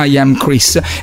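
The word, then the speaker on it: IAMX: I am Chris, sostanzialmente si legge I am X, però si pronuncia I am Chris.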